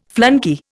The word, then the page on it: flunkey: Indian